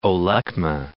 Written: Olekma

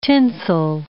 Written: tinsel

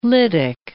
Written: lytic

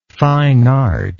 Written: fine art

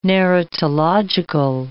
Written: narratological

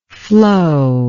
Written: flo

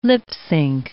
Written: lip-sync

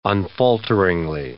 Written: unfalteringly

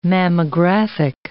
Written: mammographic

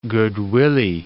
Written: guid willie

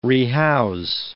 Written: rehouse

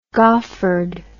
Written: goffered

